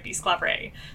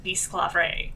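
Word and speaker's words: Bisclavret: And the song came about because For English speakers, I think, or modern English speakers, there's something kind of difficult about pronouncing Bisclavre. – Well, it's that S-C-L-A section. – Yes. – Skla. – Yeah. – That's not something we really say.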